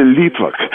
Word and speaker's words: литвак: А вот, кстати, литвак. Обратите внимание, ударение на первое слово.